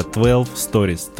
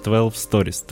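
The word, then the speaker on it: 12 Storeez: Мы узнали, как это читается. Да, наконец-то, ребята, 12 Stories, 12 Stories, 12 да, Stories.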